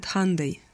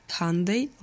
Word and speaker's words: Hyundai: В Америке произносят Hyundai. Hyundai like Sunday.